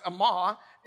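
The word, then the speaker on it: amah (אמה): So as we look at this verse, we need to understand that cubit in Hebrew means amah. The word is pronounced amah, and it means mother or the beginning.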